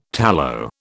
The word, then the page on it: tallow: British English